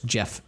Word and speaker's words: Geoff: So on Buzzkill, The Paybags, and then Crossover, he has the same artist, Jeff Shaw. And it's spelled like Jeff, G-E-O-F-F, which always reminds me of Jeffrey from The Fresh Prince.